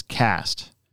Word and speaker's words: Quast: And then uh, Kyle, and so the last name, I never even asked him how to pronounce it, was uh, Q U A S T, and I think it's cast. I think that's how you pronounce it. Oh. – Otherwise, it would be Quest or Quest. I think it's Cast though.